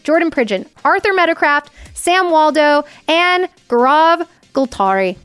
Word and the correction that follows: Golgari: That's not, I didn't say that right. Gultai. I'll have to ask. – Golgari.